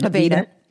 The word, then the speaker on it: Aveda: Aveda.